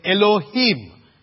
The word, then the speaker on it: Elohim: And the name of God that is used here is the Hebrew name that is called, we would pronounce it this way, Elohim.